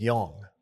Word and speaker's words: Jung: It's Young. – Young? Well, because you're thinking of the psychiatry.